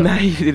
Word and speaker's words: Donaire: Donaire le dicen. – Sí, Donaire se dice. – Ah, Donaire. – Pero se escribe Donaire.